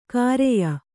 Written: ಖರೆಯ

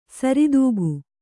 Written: ಸರಿದೂಗು

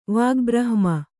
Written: ವಾಗ್ಬ್ರಹ್ಮ

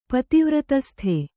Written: ಪತಿವ್ರತಸ್ಥೆ

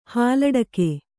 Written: ಹಾಲಡಕೆ

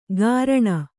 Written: ಗಾರಣ

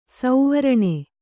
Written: ಸುವರ್ಣ

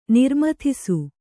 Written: ನಿರ್ಮಥಿಸು

